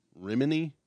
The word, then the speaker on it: Rimini: Rimini. – Rimini? – Yep. – Leah Rimini? Leah Rimini, Italy. R-I-M-I-N-I.